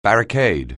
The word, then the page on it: barricade: anglais